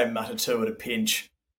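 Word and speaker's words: Matatū: the M- Matatu, is that how you pronounce it? – Yeah.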